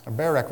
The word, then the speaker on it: Barak: He says, Barak, the son of, Barak, rather, the son of Abinoam, accident on the first syllable,